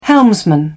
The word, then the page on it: helmsman: British English